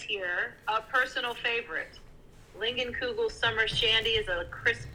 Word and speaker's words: Leinenkugel: Okay, so coming in at number 3, I don't know how to pronounce this, Leinenkugel? – Oh, Leinenkugel Summer Shandy.